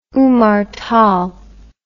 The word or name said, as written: Umar Tal